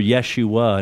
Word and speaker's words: Yeshua: Well, Joshua is pronounced this in the Hebrew, Yeshua, and it means this, Yahweh saves.